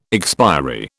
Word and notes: expiry: British English

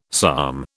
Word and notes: psalm: British English

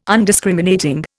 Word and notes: Undiscriminating: Indian

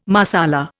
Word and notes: Marsala: Indian

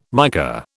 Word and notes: mica: British English

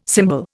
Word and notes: symbol: Indian

